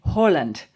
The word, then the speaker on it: Haaland: Yeah, Lindsay and I are in this camp, aren't we, that the correct way to say his name is Erling Haaland.